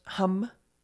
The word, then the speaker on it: ham: Today's mantra is actually going to be the word hum, which is spelled H-A-M, but pronounced hum, like as in humming.